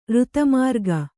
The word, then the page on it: ಋತಮಾರ್ಗ: Kannada